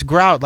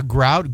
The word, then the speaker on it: Grout: I said, "It's Grout, like the cement between the tiles in your bathroom."